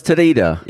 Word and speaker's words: Toteda: Right, well, Nicholas Toteda, did I say it right? – Toteda, yeah.